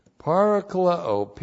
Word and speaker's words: parakaleo: Parakaleo, P-A-R-A-K-A-L-E, long O, parakaleo.